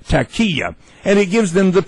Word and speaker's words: Taqiyya: The word is, I'll spell it. You all can look it up for yourself. T-A-Q-I-Y-Y-A. Taqiyya. Taqiyya. I broke it down. It's like tack, and the emphasis is on the K.